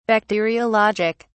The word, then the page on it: bacteriologic: British English